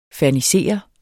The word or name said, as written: Ferniserede